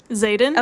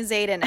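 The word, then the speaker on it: Xaden: And similarly with Zayden, Is that how you say it? – Zayden? – Okay, we do say it the same.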